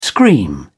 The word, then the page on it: scream: British English